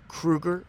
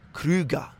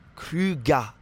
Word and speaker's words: Krüger: Now, this is not Krueger, right? Like Freddy Krueger. It's going to be Krüger. Krüger. Okay, the ER, you don't really pronounce it, so it's Krüger.